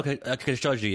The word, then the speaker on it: Khashoggi: Oh, no, Khashoggi, yeah. – Okay, I feel like Americans generally say Khashoggi, and then I didn't want to sound too misinformed, but bottom line, you know, that was embarrassing.